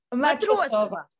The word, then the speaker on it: Matrosova: Matrosova.